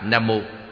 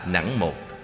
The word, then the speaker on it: Nam Mô: Chữ Nam Mô tiếng Phạn là Nam Mô, cũng đọc là Nẵng Mô, dịch nghĩa là cung kính hướng về.